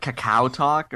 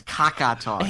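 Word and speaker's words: KakaoTalk: I'm pretty sure it's like Kakao Talk or Coke. I don't think it's KakaTalk.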